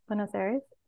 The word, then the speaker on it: Buenos Aires: Buenos Aires.